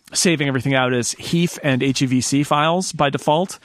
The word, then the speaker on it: HEIF: We have to say HEIF. That's okay. – How they say it. It's HEIF.